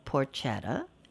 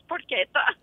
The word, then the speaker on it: porchetta: So I did not know the real definition of porchetta. Am I saying that right? Mm-hmm. Or is it porchetta? – No, you're not. It's porchetta.